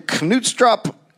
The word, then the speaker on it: Knutstrup: And his twin brother were born in Knutstrup Castle. I will never forgive Scandinavian countries for doing that shit with what should be a silent K. Knutstrup, Knut Castle, Scania, Denmark.